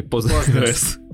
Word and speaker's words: Postgres: Для начала нужно разобраться все-таки Postgres или Postgres. – Правильное произношение Postgres. Она называется PostgreSQL, но сокращенно это Postgres. Это есть комментарий от разрабов, как правильно говорить.